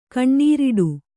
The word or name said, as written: ಕೊನರಿಡು